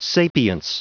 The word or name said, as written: sapience